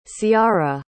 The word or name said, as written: ciarra